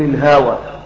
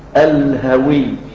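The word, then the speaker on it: الهَوِيّ: تقراها بعد كده تقول الهوى، هي مكتوبه كده تنطق كده، لكن هي اللفظ مشكل الهوي.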